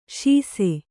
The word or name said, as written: ಸಾಸ